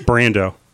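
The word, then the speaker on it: Brandow: John Brando Trumbull. – Seriously? – Brando. Not spelled the same way as Marlon, but with a W at the end.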